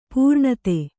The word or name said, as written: ಪೌರನೀತಿ